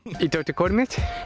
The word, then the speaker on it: Ittoqqortoormiit: Itokotomit. Okay, we'll let the guy say it. – Itokotomit? Itokotomit.